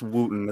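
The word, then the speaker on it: Wootton: It's W O O T T O N. – Yeah, it's Wooten or Wooton. – Wooten. Like that's, that. that's what it is. It's Wooten.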